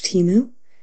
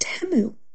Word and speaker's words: Temu: It's not pronounced Temu. It's pronounced Temu.